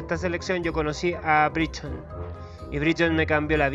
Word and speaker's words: Brereton: Breveton, Breveton, Bredeton, Briton. Así se pronuncia realmente.